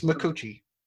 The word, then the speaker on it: Micucci: Kate Micucci. – Did you say it right? – I did say it right. It's Micucci.